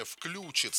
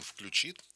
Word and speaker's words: включит: И совсем недавно ударение «включит» стало допустимым при строго правильном «включит».